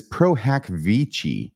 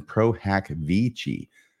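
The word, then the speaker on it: pro hac vice: And it says Pro Hac Vici forthcoming. At least that's how I learned to pronounce that in law school at the University of Texas at Austin. Pro Hac Vici.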